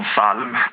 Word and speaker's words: psalm: Men till exempel så har vi ord som psalm till exempel, eller ljud, där vi då stavar LJ på ljud och psalmen med P, men vi uttalar inte P, det är liksom stumt.